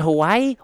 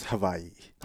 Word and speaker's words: Hawaii: Technically, it's Hawaii. – Hawaii? – Yeah. – Like a V? – Yeah. – Oh. – Yeah, wow. In the Hawaiian language, W's are pronounced with a V.